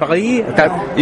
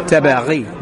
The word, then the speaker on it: Tabary: Tabary, how do you pronounce it? Tabary? – In French, we say Tabary. – Tabary. – But in America, you say Tabary.